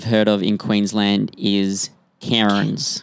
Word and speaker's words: Cairns: Spelled C-A-I-R-N-S. – But because that makes, yes, sense. – Canadians like to pronounce every single letter. – Yes, they do. – Why have an alphabet if you're not going to say all those all those letters? – Takes too long, mate.